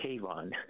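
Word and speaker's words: Kavon: No, it's K-A-V-O-N, like Avon with a K.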